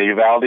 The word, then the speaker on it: Uvalde: Yeah, Uvalde, yeah.